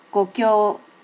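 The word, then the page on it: gokyo: native Japanese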